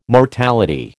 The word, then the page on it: mortality: American English